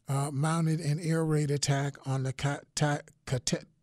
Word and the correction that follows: Kataib Hezbollah: how do you say it again? Kataib Hezbollah militia, which was then followed by pro Iranian militiamen attacking the U.S.